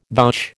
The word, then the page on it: vouch: American English